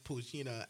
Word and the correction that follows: Pochita: I think you're saying it wrong. – He is saying it wrong, but we got What is this? – It's Pochita. – Pochita.